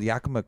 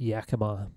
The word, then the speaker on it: Yakima: I believe most people pronounce it Yakima.